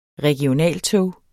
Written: regionaltog

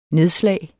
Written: nedslag

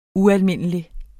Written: ualmindelig

